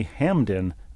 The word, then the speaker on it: Hampden: I can, as someone who lives near Baltimore, confirm that there is an annual Hun Festival held in the Hamden neighborhood, H-A-M-P-D-E-N, but the P is silent.